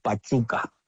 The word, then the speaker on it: Pachuca: Pachuca, is that how you pronounce it? – They pronounce it Pachuca.